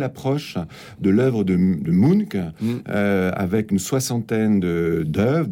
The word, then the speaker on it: Munch: Donc, Munch, M-U-N-C-H, qu'on prononce en général Munch, qui est un artiste d'origine norvégienne que on connaît bien, bien sûr, par ce fameux tableau qui s'appelle Le Cri.